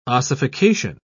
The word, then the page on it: ossification: British English